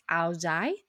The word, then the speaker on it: Ao Dai: Note that this is the pronunciation used by folks hailing from the southern part of Vietnam, whereas in the northern part of Vietnam, you may hear it pronounced as Ao Dai. So for this episode, we'll be using the southern pronunciation.